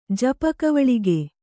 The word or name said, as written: ಜಪಕವಳಿಗೆ